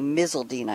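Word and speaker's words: Miseldine: My 2 esteemed panelists are Professor Olivier Oulier, O-U-L-L-I-E-R. He's PhD. And Phil Misseldine, if you're looking for him, it's M-I-S-E-L-D-I-N-E, Misseldine, I pronounce it now correctly.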